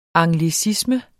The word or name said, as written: anglicisme